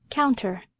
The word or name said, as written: counter